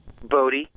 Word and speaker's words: Bodhi: Do you pronounce it Body or Bodhi? – Bodhi. – That's what I was saying was Bodhi. – It's like a Buddhist-y thing.